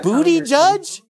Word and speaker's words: Buttigieg: It's booty judge. – Buttigieg.